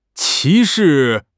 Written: 歧视

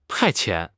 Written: 派遣

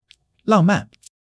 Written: 浪漫

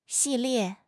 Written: 系列

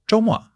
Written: zhou1 mo4

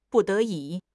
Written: 不得已

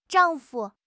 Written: zhang4 fu5

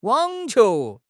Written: wang3 qiu2

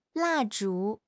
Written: 蜡烛

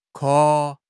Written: ke1

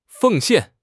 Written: 奉献

